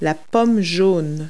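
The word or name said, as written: yellow apple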